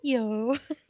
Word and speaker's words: Petiot: Okay, so literally, um, the way to pronounce his last name in my notes is Pet hyphen Yo, but snooty. Petiot.